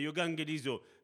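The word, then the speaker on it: yogangelizo (εὐαγγελίζω): Et quand on prend euh, la bonne nouvelle, par exemple, ben, en grec, ça se dit yogangelizo. Il y a des erreurs, je veux dire, dans ma prononciation, c'est yogangelizo.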